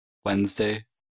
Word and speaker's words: Wednesday: Не читается в сочетаниях and в составных словах. Например, sandwich, grandmother, grandchild, Wednesday, handsome, handbag, handkerchief.